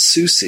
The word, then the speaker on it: SUSE: Another popular distribution, especially in Europe, is SUSE. It's spelled S-U-S-E.